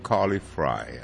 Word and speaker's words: Frey: It's spelled F-R-E-Y, and you would think that is Frey, but I'm going to go with Carly Fry. – I believe that's how she pronounces it. Like, well, fries, like French fry.